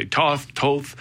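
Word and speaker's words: Tóth: Like some people say Toth, and some Hungarians say Tot.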